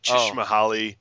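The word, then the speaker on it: Csikszentmihalyi: I tried to pronounce, and then Dan corrected me. It's like Chishmahali. Oh yeah, Chich- yeah.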